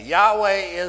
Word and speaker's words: Yahweh: Yahweh is the proper way to pronounce it, and that's just the way it is.